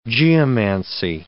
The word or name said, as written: geomancy